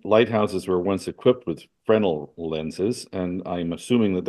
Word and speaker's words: Fresnel: And so the revival of the wave theory of light was cemented by the gifted French physicist Augustin Jean Fresnel. Now, we pronounce that in here as Fresnel, but it's really Fresnel, whose remarkable research in optics led to the almost unanimous acceptance of the wave theory of light.